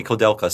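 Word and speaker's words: Koudelka: I mean, he was part of whoever made what preceded Shadow Hearts, Koldeika. – Oh, really? – That was his company, Kodelka. Sorry, I always get the name wrong.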